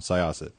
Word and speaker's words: Syosset: Well, the problem with this, Marty, and I appreciate your elucidation of these topics, but also the correction on the pronunciation of Syosset. So I've got it now, Syosset.